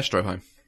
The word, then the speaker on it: Stroheim: Stroheim? Guy who played Max? – Oh, Eric von... yeah, Stroheim.